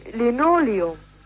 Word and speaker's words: Linoleum: Ich möchte gerne wissen, wie man das betont. Das ist hier also Linoleum zur Auswahl und Linoleum, ja? Jetzt fehlt mir da die richtige Betonung und da wüssten Sie doch sicherlich Bescheid. – An für sich haben wir ich für meinen Teil sage Linoleum. – Ja, und das ist auch amtlich richtig, Ja, das kann ich Ihnen nicht sagen.